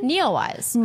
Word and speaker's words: NEOWISE: How do you say it again? – Neowise.